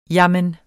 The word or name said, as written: jamen